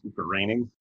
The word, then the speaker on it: Groening: Yeah, I think it's because his name is spelled like groaning, mm-hmm. but it's pronounced graining.